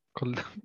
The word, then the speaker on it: Collini: Eh, med Adrian Collin, Collini. Collini. Collini. ja, det nya uttalet.